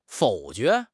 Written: 否决

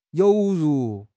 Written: 犹如

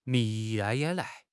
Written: 眯

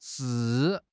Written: shi4

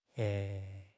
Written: 嘿

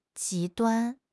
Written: 极端

